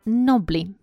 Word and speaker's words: knobbly: Number eight – nobly. Nobly knees. Это такие узловатые колени. Nobly пишется K-N-O-B-B-L-Y, но буковка K в начале не произносится nobly knees. И, кстати, в слове колени K-N-E-E, e, буковка K тоже не произносится nobly knees.